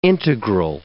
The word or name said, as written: integral